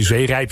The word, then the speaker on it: Zeerijp: Nou, als je Zeerijp zegt, dan benadruk je de tweede lettergreep meer dan de eerste lettergreep.